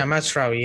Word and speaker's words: Mazraoui: In Mazraoui, am I pronouncing it right? – Yeah, Mazraoui, yeah.